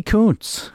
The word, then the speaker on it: Kuntz: You know, it's Rusty Kuntz. And that is how you say his name, by the way, K-U-N-T-Z.